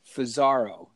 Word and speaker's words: Fusaro: The old at symbol followed by F like Frank, O-O, Z like Z-A-R-O, Fusaro. So it's at Fusaro. It's my name phonetically because everyone pronounces it wrong. It's actually spelled F-U-S-A-R-O, but...